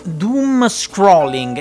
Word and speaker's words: doomscrolling: Doom Scrolling. Esatto, l'ho detto bene? Sì, sì, hai Doom Scrolling.